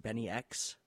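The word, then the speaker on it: Bynx: And then we're going to follow that up with another remix, Help I'm Alive by Metric, remixed by, I believe it's pronounced Benny X. I think he did some production for like Travis Scott and Yeet and people like that. But I could be wrong. It's spelled B-Y-N-X. I thought it was spelled B-N-Y-X for Benny X, but I could completely be wrong.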